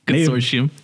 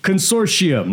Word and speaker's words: Consortium: The Consanitarium. – Consortium. – Consortium. Native Biodata Consortium.